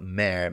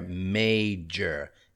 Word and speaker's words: major: This one often causes people problems. Not mare. Major. M-A-J-O-R. Major. Yeah, major. Advertising. I-N-G.